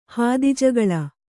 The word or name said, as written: ಹಾದಿಜಗಳ